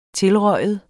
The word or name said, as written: tilryge